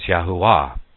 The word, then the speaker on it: Yahuwah: But we know that the J isn't pronounced in the Hebrew either, and neither is the V. So his name is Yahuwah.